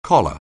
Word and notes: collar: anglais

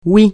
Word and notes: oui: francais

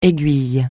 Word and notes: aiguille: français

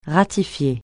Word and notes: ratifier: francais

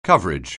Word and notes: coverage: anglais

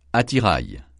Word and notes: attirail: francais

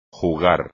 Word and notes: jugar: Spanish

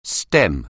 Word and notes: stem: anglais

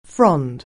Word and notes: frond: anglais

